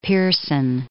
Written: Pearson

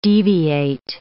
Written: deviate

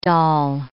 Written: doll

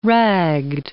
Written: ragged

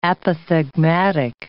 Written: apothegmatic

